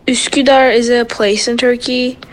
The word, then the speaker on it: Üsküdar: Üsküdar, that's how you pronounce it.